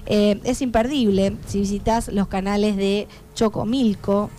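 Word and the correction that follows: Xochimilco: no, perdón, Cochimilco, es muy complicado pronunciar, y se encuentra dentro del conteo de los lugares más embrujados del mundo.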